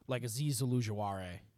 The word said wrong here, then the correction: Ojulari: I think I just said his name wrong. – Ojulari. Yeah. – I'm sorry. It's late. You're good. – Ojulari.